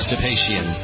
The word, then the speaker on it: Sib Hashian: Sib Hashian.